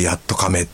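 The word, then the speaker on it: 八十日目: あ、やっと亀ってのはあの愛知県の方のね、やっと亀だな、なもとか言うんですけども、やっと亀ってのは 80 日目と書いてやっと亀って読むんですけどもね。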